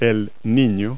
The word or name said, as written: boy